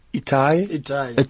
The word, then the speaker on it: Itai: But having to say that, let's try and put this on a hold and bring in our esteemed guest tonight, Etier. Malchior, am I pronouncing it? Itai.